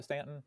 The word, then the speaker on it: Staunton: You say Stanton is spelled Staunton, you say it Stanton, right?